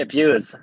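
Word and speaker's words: Bures: Yeah, so you grew up in, is it Burris or Buers? How do you pronounce Buers. – It? – Yeah, Buers, actually. – Buers, yeah. Gotcha. – Well done. A lot of people don't get that right, so you've done well.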